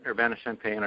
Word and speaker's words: Urbana-Champaign: Did I say that right, Urbana-Champaign? I love the way that sounds. – Yeah, Urbana-Champaign or Champaign-Urbana.